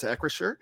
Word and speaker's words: Acrisure: Danny Don Hawker, I hope I pronounced that right, asked how it's pronounced, and it's Acrisure. We're pretty sure it's Akrasher.